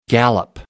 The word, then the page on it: gallop: American English